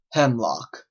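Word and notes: hemlock: Canadian English

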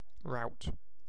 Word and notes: rout: British English

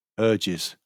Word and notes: urges: British English

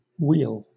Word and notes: weal: British English

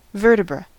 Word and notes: vertebra: American English

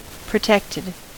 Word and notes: protected: American English

